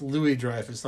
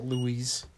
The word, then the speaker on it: Louis-Dreyfus: It's Louis-Dreyfus, not Louise.